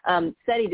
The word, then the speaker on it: Setti: But, Setti, did I even say your name right? – You sure did.